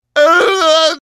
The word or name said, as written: uwu